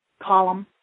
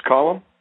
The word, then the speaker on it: Colm: Colum. – His name is Colum? – Colum. – How do you spell that? – C-O-L-M. – Okay, C-O-L-M, Colum? – Yeah.